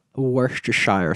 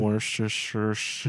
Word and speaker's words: Worcestershire: Worcestershire sauce. – Yeah. Yeah, I have something very difficult to say to you. – Did I say it wrong? – Worcestershire.